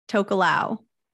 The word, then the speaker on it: Tokelau: I'm going to butcher this, and I'm prefacing that. New? And Tokelau. – Yep. Help with the pronunciation.